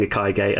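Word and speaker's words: ikigai: And I keep saying ikaige, and I'm sorry if I'm butchering how it is pronounced. – No, I think you're saying it right, actually.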